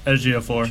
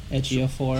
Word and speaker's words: Ejiofor: I like Lupita, and I like, I don't know how to pronounce his name, but Ejiofor. – Ejiofor.